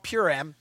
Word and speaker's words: Purim: All right, so this week in the Jewish culture is called, I'm going to say it wrong, Purim, okay? It's called Purim.